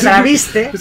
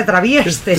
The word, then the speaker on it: traviste: Es que, ¿cómo, cómo, cómo se dice? Se traviste. Se Y tú has dicho se travieste.